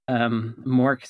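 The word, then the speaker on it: Mørk: Lastly, I will recommend the new Mork album. Mork spelled like Mork and Mindy.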